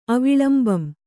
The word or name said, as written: ಅವಿಲಂಬಂ